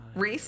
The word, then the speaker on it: Rhys: Reese, spelled like Reese. – R-H-Y-S.